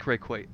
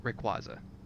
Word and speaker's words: Rayquaza: I like Rayquaza. Okay, Rayquaza. – Cool. – Qwah. Qwah. It's Qwah. – Qwah? – Yeah, Q-U-A, Qwah.